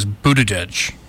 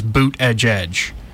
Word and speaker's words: Buttigieg: Yeah, I think the way you actually pronounce his name is Buttigieg. Or they're selling a shirt that says Boot Edge Edge. – Okay. – So it's Buttigieg or something like that. Like, say boot and edge edge quick.